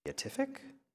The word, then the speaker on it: beatific: Um, specifically the quote incommensurate good of the beat, beatif- uh, how do you say that beatific beatif- beatific okay vision thank you uh,